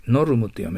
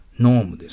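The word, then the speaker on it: norm: その次はですね、ローマ字読みしてみてください。ノルムって読めるでしょ。ノームです。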